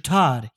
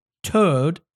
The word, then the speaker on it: Toad: This is not Mr. Todd. He is Mr. Toad, or pronounced tote bag with a D.